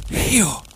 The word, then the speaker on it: Rio: Ben, au Brésil, ça se prononce Rio.